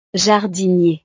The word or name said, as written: jardinier